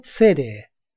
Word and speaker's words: city: اگر درست دقت کرده باشید، city یعنی شهر اینجوری تلفظ شده. City. City. در خیلی از مواقع آمریکایی‌ها T یا double T رو به D تلفظ می‌کنند.